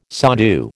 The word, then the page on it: sadhu: American English